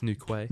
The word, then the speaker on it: Newquay: I can pronounce Newquay. – Okay, yeah. – It's Newquay.